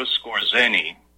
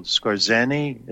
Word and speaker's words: Skorzeny: Bush was there as a 19-year-old kid watching while Skorzeny, as it's pronounced, Skorzeny suffocated Tesla.